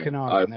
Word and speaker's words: Okanagan: You've got the um, I'm gonna hope we're gonna pronounce it right. The Okanagan Hockey Academy. – Yeah, so the Okanagan Hockey Academy. Okanagan, there we go.